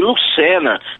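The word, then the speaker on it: Lucenna: e Lucena, tem dois N's no final, é MarcusLucenna.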